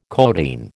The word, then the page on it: codeine: American English